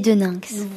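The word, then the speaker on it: Daeninckx: Beinix. – En fait, ça se dit Didier Deninx. – Voilà, Deninx. Je le prononce toutes les lettres.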